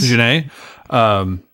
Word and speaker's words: Jeunet: I chose Jean-Pierre Jeunet, I'm not sure how to say his last name. – Jeunet.